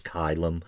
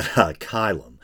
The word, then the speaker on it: caelum: I think the A-E is pronounced as I in Latin. Because I think, is it heaven or the firmament? Is looks like Calum, C-A-E-L-U-M or something like that, but it's actually pronounced Kylum.